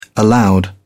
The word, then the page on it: aloud: British English